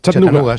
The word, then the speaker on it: Chattanooga: ¿Se pronuncia así en Chattanooga? – Chattanooga, sí. Chattanooga. – Chattanooga. – Chattanooga City. Sí, sí, ya somos... – Chattanooga Chuchu.